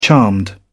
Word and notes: charmed: British English

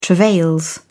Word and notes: travails: British English

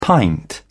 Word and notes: pint: British English

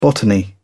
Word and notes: botany: British English

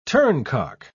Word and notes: turncock: British English

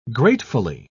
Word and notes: gratefully: British English

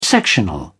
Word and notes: sectional: British English

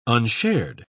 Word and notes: unshared: British English

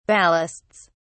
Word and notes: ballasts: British English

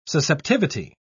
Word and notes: susceptivity: British English

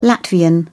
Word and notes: latvian: British English

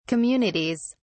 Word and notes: communities: British English

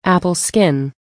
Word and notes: apple skin: American English

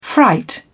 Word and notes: fright: British English

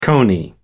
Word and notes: coney: American English

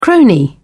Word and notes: crony: British English

